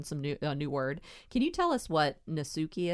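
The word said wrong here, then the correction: Netsuke: is? Am I saying that right? – Oh, yes. Yeah. Natsuke. – Yeah, okay. Natsuke. So I'm saying it wrong.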